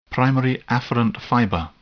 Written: primary afferent fiber

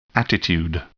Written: attitude

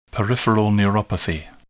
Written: peripheral neuropathy

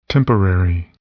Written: temporary

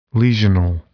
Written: lesional

